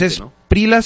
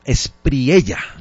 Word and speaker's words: Espriella: Hay que pronunciar bien el nombre. – También cantante, ¿no? – No es Esprila, sino Espriella.